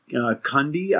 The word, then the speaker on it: Cundey: And somehow they talked Dean Cundey, I believe is how you say his last name.